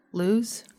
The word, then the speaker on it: Luz: Is it L-U-Z? – Uh-huh. – Luz. – They pronounced it. – Did they? – Uh-huh. – Luz? – Luz, yeah.